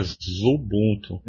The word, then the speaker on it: Xubuntu: Só para abrir um parênteses aqui, com um momento curiosidades curiosas, embora a gente escreva Xubuntu, a pronúncia seria Zubuntu.